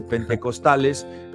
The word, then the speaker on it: pentecostales: o que si por qué los pentecosteses, que no se dice pentecosteses, se dice pentecostales, eh, eh, hacen un uso de los dones.